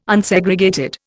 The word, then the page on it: Unsegregated: Indian